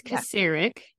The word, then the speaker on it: Kucirek: It's Kucharik.